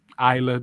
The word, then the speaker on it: Isla: but I guess the S is silent, and that's dumb. – Isla, the S is silent, stupid.